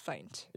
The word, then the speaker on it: Feindt: My last name is pronounced Feindt. – Yeah. – You do say the end.